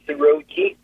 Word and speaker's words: Rogi: It's Rogi, R-O-G-I.